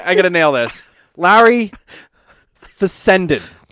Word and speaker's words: Fessenden: Doesn't sound right. Hold on, say it again. – It's F-E-S-S. – Larry Fessectomy. – Here, let me spell it. It's F-E-S-S-E-N-D-E-E-N. – Fessenden. – Fessenden. – Fessenden. – Larry Fessenden. – Trying to pronounce every single letter in the guy's name. – Pronunciation is my thing. Like, I can't skip a letter. I can't blur them.